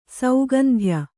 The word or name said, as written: ಸೌಗಂಧ್ಯ